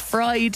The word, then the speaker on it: Fridh: Now, when Pauline discovered that her future husband's name was Bacon, she found this hilarious because she's from Sweden and her maiden name is F-R-I-D-H but pronounced fried.